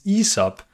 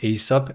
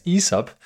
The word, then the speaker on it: Aesop: And the correct pronunciation, that's another thing I learned in this research, is Aesop, not Aesop, as I've been calling it ever since I learned about it. But yeah, it's Aesop.